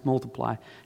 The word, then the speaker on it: Multiply: The second album, Multiply, but it's just like it's a little X. But it's pronounced Multiply, has sold already 10 million copies.